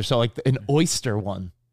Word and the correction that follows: Oster: Oster. – You call it oyster? – Well, it's spelled O-Y-S-T-E-R, right? – No. – What is it spelled? How's it spelled? – Oster. – O-S-T-E-R? – You put a Y in there.